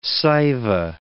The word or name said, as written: Saiva